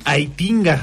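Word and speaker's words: Heitinga: Ajax tiene nuevo entrenador, se trata de John Aitinga, así se pronuncia mi estimado Rolas.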